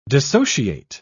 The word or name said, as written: dissociate